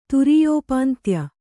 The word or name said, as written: ತುರೀಯೋಪಾಂತ್ಯ